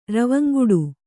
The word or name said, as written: ರವಂಗುಡು